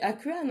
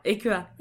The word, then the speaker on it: Aqua: mais euh, c'est AQA, non C'est Aqua Ouais.